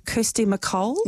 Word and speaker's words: Kirsty MacColl: correct me if I say this wrong, Kirsty MacColl. – Yes. – That's how we say it.